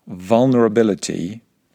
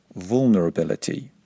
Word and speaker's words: vulnerability: Okay, if you want to speak with standard, non-regional British English pronunciation, then you would pronounce the word vulnerability with a short U sound at the beginning. Be careful not to pronounce it vulnerability.